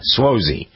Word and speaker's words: Suozzi: On the people list, Tom Suozzi, is that how you pronounce his name, Jen? Suozzi? – The congressman, uh-huh. – Yeah, Suozzi.